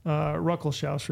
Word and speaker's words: Ruckelshaus: Ruckelshaus review.